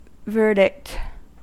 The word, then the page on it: verdict: American English